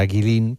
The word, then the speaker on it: Guilin: Bueno, esta ciudad de Guilin Guilin, por si alguien la busca en internet, se escribe G-U-I-L-I-N, como si fuera Guilin, pero se pronuncia Guilin.